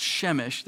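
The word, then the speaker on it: Przemyśl: And then this one looks like Przemysl. It's actually pronounced Chemish. This is why the Polish people get a bad rap, I think. That is not Chemish. That's Przemysl.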